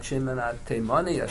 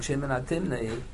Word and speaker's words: Shimon HaTimni: So we paskin like Shimon HaTimoni or Shimon HaTimni, however you pronounce his name. I think Rashi says that it's not Teiman. He wasn't from Teiman. He was from Timnos, from Eretz Yisrael.